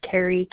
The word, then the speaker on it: Kari: Last name is spelled P as in Paul, A-G-L-E-S. My first name is Carrie, K-A-R-I, and my husband's first name is Tanner, T-A-N-N-E-R.